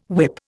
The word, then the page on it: whip: Indian